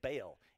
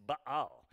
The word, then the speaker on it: Baal: A lot of people mispronounce it. They say Baal. It's not Baal. It's Baal.